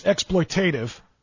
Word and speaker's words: exploitative: What we're asking these players to do with their bodies is exploitative. And so, okay, first of all, the word, Mr. Kellerman, is exploitative. Learn the emphasis and the accent on the right syllable.